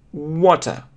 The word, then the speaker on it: water: Опять, вы знаете, что на конце читается обычно не просто, то есть на конце, вот когда и буква и и R не произносится просто как такая слабая э. Water. Water, water. А это означает «о», но двоеточие долго. Water, water.